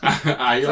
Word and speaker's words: Aiel: That's one word, that man. – Aiel. How do you say it? Aiel. – What did I say? – Aiel. You made it two words. – Ah, all right. I put a gap in. Yeah. But I pronounced it right. Just I left a gap.